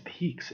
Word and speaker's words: pique: How did you pronounce that word spelled P-I-Q-U-E? – Pikes? Peaks? Pikes? – I believe it's Peaks.